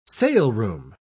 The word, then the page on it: saleroom: British English